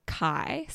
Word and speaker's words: Khy: It's called Khy, spelled K H Y. Mind blowing. The silent H symbolizes destiny, good fortune, and spirituality, according to a it statement.